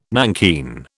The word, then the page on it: nankeen: British English